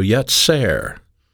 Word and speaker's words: yetzer: The word inclination is a noun pronounced in the Hebrew yetzer, and it simply means their intentions or their plans.